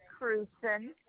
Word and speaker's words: Krewson: Bonnie Krewson. K-R-E-W-S-O-N. – It was K-R-E-W-S-O-N? – Yes.